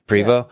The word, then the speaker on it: Prevost: Because I bet you Carolyn Prevost, am I saying her name right? I think she's going to do. Prevost. Yeah. She's going to do incredible in that last event.